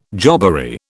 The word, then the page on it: jobbery: British English